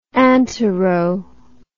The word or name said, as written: antero